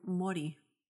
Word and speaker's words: Māori: Everyone would just think you're Maori. It'd be shitty to you, though. – Yeah, probably. But 100%. I would be working in Maori community. – Oh, that'd be nice. – Or Māori.